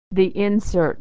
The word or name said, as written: the insert